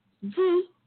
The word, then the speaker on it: Yhi: Por ejemplo, Yi, que se escribe YHI en Australia.